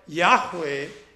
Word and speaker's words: Yahweh: Hallelujah is praise Yahweh. And that's why Yahweh is the most appropriate way to pronounce that name.